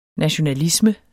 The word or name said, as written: NATIONALISME